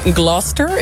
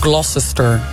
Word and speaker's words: Gloucester: Je hebt in Engeland, in Gloucester. Ik heb op deze uitspraak geoefend. Want het is zo'n Gloucester naam. – Ja, precies. Net zoals Worcestershire.